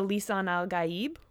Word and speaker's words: Lisan al-Ghaib: Then he is the Lisan al-Ghaib,